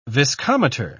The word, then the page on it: viscometer: British English